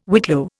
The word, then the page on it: whitlow: Indian